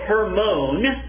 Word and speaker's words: Hermon: And then he says, it is like the dew of Hermon, that's how you would pronounce that, they tell me in Israel, it's like the dew of Hermon coming down upon the mountains of Zion.